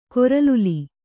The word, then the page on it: ಕೊರಲುಲಿ: koraluli